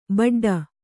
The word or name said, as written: ಬಡ್ಡು